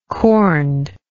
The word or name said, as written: corned